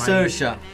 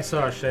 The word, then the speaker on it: Saoirse: Saoirse. – Saoirse, esatto.